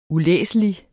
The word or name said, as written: ulæselig